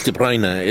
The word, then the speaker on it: De Bruyne: I'm talking about if De Bruyne, or, or as yeah. some commentators call him, De Bruyne. – It's De Bruyne.